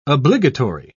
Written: obligatory

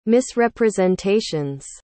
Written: misrepresentation's

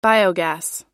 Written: biogas

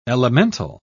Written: elemental

